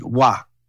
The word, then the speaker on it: wa: And when you ask someone, are you okay, or is that fine, they will just say wa, which is spelled W-A, and wa.